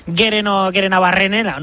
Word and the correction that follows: Gerenabarrena: ¿no? – Bueno, pues Gerenabarrena. Ahí está.